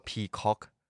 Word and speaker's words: Peacock: Peacock